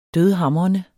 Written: dødhamrende

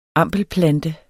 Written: ampelplante

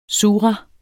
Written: sura

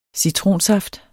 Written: citronsaft

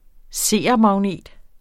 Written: seermagnet

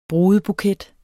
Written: brudebuket

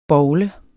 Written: BOWLER

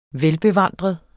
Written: VELBEVANDREDE

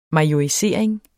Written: majorisering

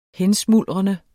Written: hensmuldrende

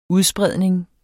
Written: udspredning

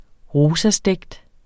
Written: ROSASTEGT

